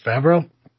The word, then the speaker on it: Favreau: Favreau? – Mm-hmm.